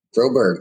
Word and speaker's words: Froberg: Froberg. That's right. Exactly how it's spelled.